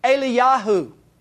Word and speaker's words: Eliyahu: Elijah, the name, is actually pronounced Eliyahu in the Hebrew.